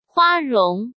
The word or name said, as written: Hua Rong